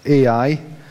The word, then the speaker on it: Ai: AI is how it's pronounced.